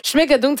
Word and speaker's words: Schmigadoon: Schmigadoon, ça s'écrit S-C-H-M-I-G-A-D-O-O-N.